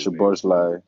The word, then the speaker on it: Szoboszlai: Szoboszlai.